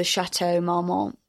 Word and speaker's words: Chateau Marmont: So this is July 26, 2006, and it's Lindsay Lohan, courtesy of the Chateau Marmont, which is a hotel, which is where she was living.